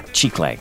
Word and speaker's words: chicle: Edward makes his gum from chicle, or chicle, as it's more commonly pronounced.